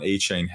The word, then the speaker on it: Achane: And I think, you know, looking at the rookie year that Devon Achane had, or Achan, I think is how you pronounce it.